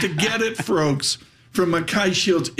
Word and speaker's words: Mackay: Am I pronouncing that right? – Got it. – Mackay, I nailed it. Mackay yes. Shields.